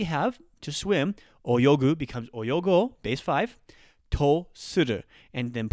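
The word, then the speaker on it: 泳ごう: so oyogu is to swim, oyogo, a long O there, is going to be to, tr- to let's swim, right?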